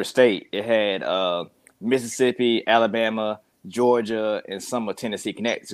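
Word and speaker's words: Jawga: In my world, it's J-A-W-G-A, you know, pronounced Georgia, or if you read it out, Georgia, you know, just a different take on it.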